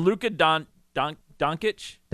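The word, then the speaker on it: Dončić: And Luka Doncic is how you pronounce his name.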